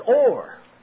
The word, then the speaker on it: or: In the third, fourth, fifth verse, the word, the Hebrew word used there is or. Or. Spelled O-W-R, pronounced O-R-E. Pronounced just like iron ore.